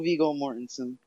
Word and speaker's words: Viggo Mortensen: starring Viggo Mortensen. Am I saying that right? – Good old Viggo Mortensen.